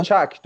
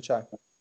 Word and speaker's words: چک: آره آره این اطلاع میکنم تلفظ چک بوده موقع. – آره چک، درسته چک.